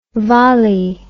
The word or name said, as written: vali